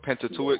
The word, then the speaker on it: Pentateuch: I mean, I've never actually studied the Torah or the, what is it pronounced, the Pentateuch? – Yeah, Pentateuch. – Pentateuch. – I'm probably not even pronouncing it all the way right.